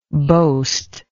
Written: boastless

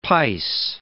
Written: pice

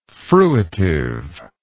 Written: fruitive

